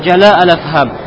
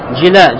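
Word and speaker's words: جلاء الأفهام: Кали ибн аль-Кайм фи джала аль-Афхам. Но сказал ибн аль-Кайм, и точнее правильно читается как джила, джила аль-Афхам. Сказал ибн аль-Кайм в своей книге джила аль-Афхам, وَإِنَّمَ دَخَلَ الْأَزْوَاجُ فِي الْآلِ وَخُصُوصًا أَزْوَاجِ النَّبِيِّ صَلَى اللَّهُ عَلَيْهِ وَسَلَّمَ تَشْبِيهًا لِذَلِكَ بِالنَّسَبِ.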